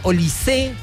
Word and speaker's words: Olise: Está Julián Quiñones, está Michel Olise, o Olise, esto no es palabra aguda, sino que no tiene acento en la última letra, en la E.